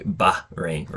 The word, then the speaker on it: Bahrain: Yeah, I think when you pronounce it, it's like Bahrain, right? Bahrain, like there's like a glottal there's a glottal stop there.